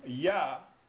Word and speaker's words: Jah: Extol him that rideth upon the heavens by his name, Jah, J-A-H, and rejoice before him. Now, Jah, the reason that you will hear people pronouncing this name, Yah, is because there's three reasons.